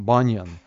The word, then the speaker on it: Баньян: Буньян, я уже забыл, как правильно говорить. Переводят Беньян, раньше говорили Буньян, теперь, когда я смотрю на английское, там Баньян.